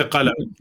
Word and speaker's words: Qalam: There's like there's also like the rural Palestinian can say Qalam with a Q. – Yes. – Right. – Yeah, we say Qalam. That's how we say it.